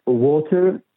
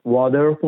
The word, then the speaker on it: water: ولی در بعضی جاها که در انتها قرار میگیره و یا در وسط قرار میگیره ممکنه تلفظ نشه، مثل کلمه water که در انگلیسی آمریکایی water خونده میشه با آر غلیظ، در انگلیسی بریتانیایی و استرالیایی water خونده میشه، یعنی آرش تلفظ نمیشه.